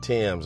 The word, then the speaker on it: Thames: His name is Brian Timms. It's spelled T-H-A-M-E-S.